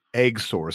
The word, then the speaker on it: XOR: It's spelled XOR, pronounced Eggsor. Some of these company names are just like really like bizarre beyond belief.